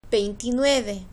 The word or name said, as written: veintinueve